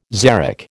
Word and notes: xeric: American English

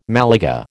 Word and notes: Malaga: American English

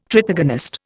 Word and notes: tritagonist: Indian

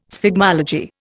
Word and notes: sphygmology: Indian

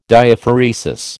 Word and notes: Diaphoresis: American English

